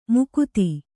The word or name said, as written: ಮಾಗದಿ